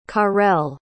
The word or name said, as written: carel